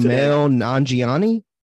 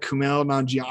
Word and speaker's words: Kumail Nanjiani: Okay, the next shot is, I'm going to butcher his name. – I practiced Kumail Nanjiani. – You got it right. Yeah. I practiced his name today. I was like, Kumail Nanjiani.